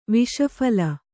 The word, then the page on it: ವಿಸ್ಫಾಲ: visphāla